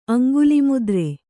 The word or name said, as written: ಅಂಗುಲಿಮುದ್ರೆ